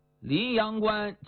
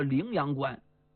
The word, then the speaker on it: 灵阳关: 临阳关其实叫灵阳关，但大家把这音呢读转了，就读成临阳关了。